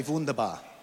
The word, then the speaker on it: wunderbar: Everyone say wunderbar. Now, is there a... Did I pronounce that correctly? Oh, good. Okay, I did.